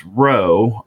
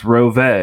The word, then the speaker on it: Rowe: One thing I learned is that the BMW team, as I formerly thought it was pronounced, Row, R-O-W-E, at least according to the announcer, it's pronounced Rove, which has a nice ring to it.